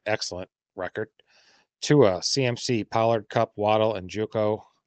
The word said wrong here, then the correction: Njoku: I said that wrong. – Njoku.